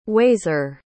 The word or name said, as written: wazeer